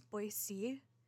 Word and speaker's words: Boise: And it's not that it's, like, obviously people from Boise know it's Boise. So, and I know that it's spelt the same way as noise and whatnot.